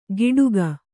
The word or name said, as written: ಗಿಡುಗ